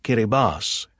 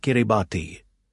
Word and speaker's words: Kiribati: Actually, it's pronounced Kiribas, Axel said, not Kiribati.